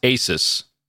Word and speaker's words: Asus: I think it's pronounced Asus. Asus. Pegasus. Because it was right. We had talked about this a few years ago. – Yeah. – I'm sure every CES it comes up, but Asus, that's just that's what it looks like.